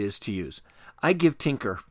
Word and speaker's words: Tynker: When you visit Tinker, That's spelled T-Y-N-K-E-R.